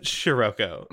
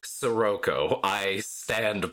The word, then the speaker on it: Sirocco: Also, they pronounce it Shiroko. – The what? – Shiroko. Uh-huh. Shiroko. – Now, Shiroko. – They do. They do it multiple times. – Shiroko. – Yep. – That's not what those letters do. – Nope. – I can't think of a language where those letters do that.